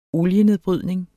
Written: olienedbrydning

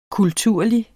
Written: kulturlig

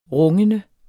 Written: rungende

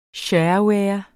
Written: shareware

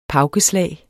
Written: pauke

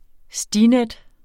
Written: STINET

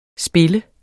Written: spiller

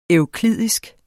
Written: euklidisk

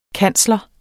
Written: kansler

